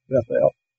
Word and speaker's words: Bethel: Bethel or Bethel, pronounced with the emphasis on the E-L for God, Bethel.